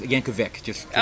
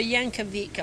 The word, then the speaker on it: Yankovic: Oh, by the way, it's Yankovic. – I'm so sorry, Yankovic. I pronounced it wrong.